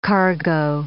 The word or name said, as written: cargo